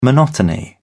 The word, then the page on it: monotony: British English